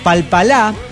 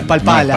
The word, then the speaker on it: Palpalá: Hoy día más, dos lugares que me resultan graciosos para visitar: Palpalá en Jujuy. – Palpalá. – Con acento en la última A, no es Palpala.